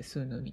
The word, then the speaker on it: swnw: 英語あの、発音が多かったんですけれども、 SWNW と書いてスーヌーみたいです。